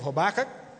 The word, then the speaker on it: Habakkuk: Take your Bible and turn to the book of Habakkuk. That's right, I did say that right.